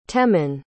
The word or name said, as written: temman